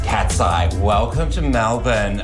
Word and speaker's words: Melbourne: Melbourne. – Of course. – Melbourne.